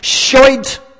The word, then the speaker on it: Schuld: In the German language, one translation of the word debt is Schuld, which means, and I know it sounds like an Irish Republic swear word, but it actually means sin. In the German language, and you can go and Google it and find out. And I know I'm saying it right because I have this thing that you push and it says it and says it and says it. And I kept practicing and practicing and practicing. Schuld.